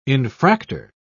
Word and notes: infractor: British English

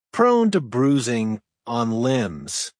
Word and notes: prone to bruising on limbs: American English

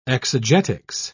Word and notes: exegetics: British English

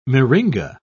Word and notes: myringa: British English

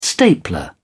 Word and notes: stapler: British English

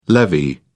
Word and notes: levee: British English

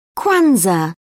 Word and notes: kwanzaa: British English

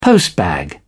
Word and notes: postbag: British English

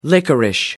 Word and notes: licorice: American English

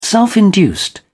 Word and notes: self-induced: British English

